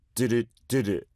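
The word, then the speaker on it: DDU-DU DDU-DU: Let's listen to Bokeh by Dreamcatcher, which addresses the toxicity of online comments, and Do Do Do by Blackpink, representing the global reach of Korean culture online. – Do-do-do-do is how you pronounce it, to be precise.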